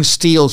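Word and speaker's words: Stihl: Steel is spelled S-T-I-H-L. S-T-I-H-L. But it's steel, like, you know, Steelers and all that. But it's S-T-I-H-L.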